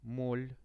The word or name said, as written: mol